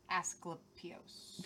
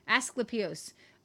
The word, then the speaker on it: Asclepios: Others take the phrase in reference to, how would you say that, God? – Asclepios. – There, thank you. Asclepios.